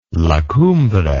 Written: La Cumbre

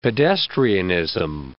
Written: pedestrianism

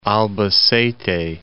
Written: Albacete

